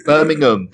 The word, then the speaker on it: Birmingham: It's Birmingham. Don't say ham at the end. You'll have all the Brits going, no.